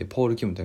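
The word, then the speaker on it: Paul Kim: K-I-M と書いてポール・キム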